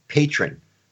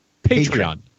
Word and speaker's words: Patreon: I understand that I'm not pronouncing it correctly, but I will always think it's patron. That's yes, what I, you know. – Patron. Patreon.